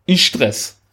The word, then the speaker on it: Istres: ich hätte das heißt Istres. – Istres. Istres. – Schwäbisch, also, Istres. Wir finden es gleich heraus. Das wird ausgesprochen. Istre. Istre, da haben wir es, hört sich ja fast, hört sich ja fast norwegisch an, Mensch.